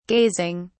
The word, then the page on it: gazing: British English